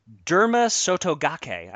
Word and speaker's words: Derma Sotogake: Angel of Empire, Forte, Raise Kane, Derma Sotogake, I believe that's pronounced.